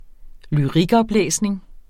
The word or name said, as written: lyrikoplæsning